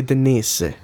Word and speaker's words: Dainese: Apparently it's pronounced Dainese. – It's actually Denise.